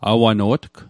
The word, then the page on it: awano'tg: Mi'gmaq